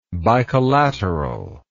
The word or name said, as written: bicollaterality